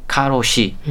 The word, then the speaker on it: karoshi: 근데 이 과로사의 영어 번역이 실제로 K A R O S H I 카로시 음. 일본어 발음 그대로 사용하고 있다고 그럽니다.